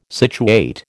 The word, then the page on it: situate: American English